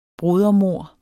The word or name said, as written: brodermord